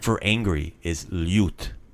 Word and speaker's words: ljut: On je ljut. – So we have ljut. That's the LJ sound. The same word as in ljubav. Same pronunciation.